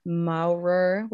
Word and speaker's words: Maurer: She refers to Todd, I assume that's how it's pronounced, T-O-D-T, 1975, Maurer, which sounds like a cat meowing, 1960, and Bandura, 1971.